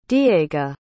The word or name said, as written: diega